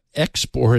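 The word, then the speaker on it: 3XP0RT: Now, we would pronounce his name export, his handle export, but it's, you know, numeral 3 XP numeral 0 RT. So, you know, in leet speak.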